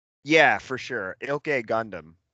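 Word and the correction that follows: Gündoğan: So it's Ilkay Gundogan. The G, Gundogan. The second G is silent, so it's Gundogan.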